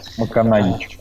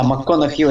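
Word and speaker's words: McConaughey: МакКонахич, как она читается. МакКонахи. Ну, ну ладно. Да, МакКонахью, это, конечно, неправильно. А,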